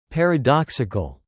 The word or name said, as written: paradoxical